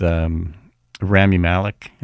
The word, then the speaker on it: Rami Malek: It's a radio play with Rami Malek. Is that how you say his name? – Yeah. Oh, yeah. Yeah.